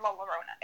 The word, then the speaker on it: La Llorona: So today I'm doing La Llorona. Remember last time I said it's like Corona with L's, and there's two L's in La Llorona. It's like La and then LLona.